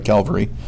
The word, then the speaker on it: Calvary: There's Gordon's Calvary, Cavalry. Yeah, I said it right, Calvary.